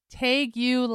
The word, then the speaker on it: tegu: It takes us to Georgia, where Georgia wildlife officials are warning residents about a growing population of invasive tegu lizards. Tegu lizards is what I said. It's spelled T-E-G-U. – Like Wagyu, except it's tegu?